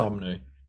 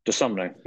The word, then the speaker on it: Dosunmu: They got Goran Dragic, but I don't see him moving the needle oodles uh, uh Sio Dum, how do you say his name? Where is the fella? Dosunmu. – Dosunmu, yeah, Ayo Dosunmu.